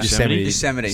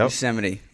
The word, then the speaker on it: Yosemite: I don't know how to say it. Yosemite? No, I don't even know how to say it. – Yosemite, sorry. National Park. Yep. – Yosemite. That's yep.